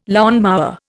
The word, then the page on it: lawnmower: Indian